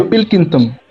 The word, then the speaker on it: Pilkington: Claro que sí, ya por último está el señor Pickinson, si ¿sí lo pronuncio bien. Sí, creo que sí. Sí, Pilkington.